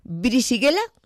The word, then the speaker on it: Brisighella: Bueno, tú vives en, a ver si lo digo bien, ¿Brisighella? – Perfecto.